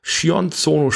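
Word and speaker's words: Sion Sono: Wir beide haben den Film Anti-Porno gesehen von Shion Tsono, spricht man den Namen aus, da legen die Japan-Nerds großen Wert drauf.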